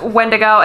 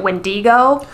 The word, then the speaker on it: Wendigo: I definitely am going to go with the one that is in Charmed because I watched it when I was 10 and it stuck. And that's Wendigo. And that's how a lot of other, like the YouTube videos and stuff that I watched, that's how they pronounced it as well, except for one where he pronounced it Wendigo.